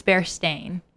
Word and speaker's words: Berenstain: It's Bear Stain? – Yeah. – Oh, okay. I know I said it wrong when I was a kid because I always called them the Berenstain Bears. – I called them the Bear Stain. Stain instead of Stain.